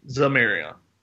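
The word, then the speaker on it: Xamarian: His name is spelled with an X. However, it's pronounced with a Z, Zamarian.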